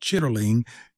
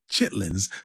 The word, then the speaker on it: chitterling: word is more accurately pronounced chitterling. We say chitlins.